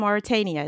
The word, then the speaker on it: Mauritania: So I mean, first I want to ask you, I know your family is from Mauritania, is that the correct pronunciation? – Yes, it is.